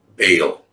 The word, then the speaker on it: Baal: B-A-A-L, Baal. Actually, we always say Baal, but if you pronounce that, that's totally the wrong pronunciation. It's two sounds. Baal, right?